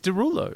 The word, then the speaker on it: Derulo: Um, it's it's Derulo. It's D E S R O U L E A U X, which is pronounced right. – Derulo.